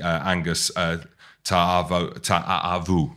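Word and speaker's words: Ta'avao: Obviously, Angus Ta'avu. That's how you say it, don't you? – Mm-hmm.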